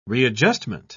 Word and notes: readjustment: British English

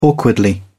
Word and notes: awkwardly: British English

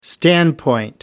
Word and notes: standpoint: American English

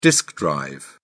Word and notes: disk drive: British English

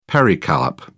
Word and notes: pericarp: British English

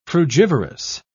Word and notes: frugivorous: British English

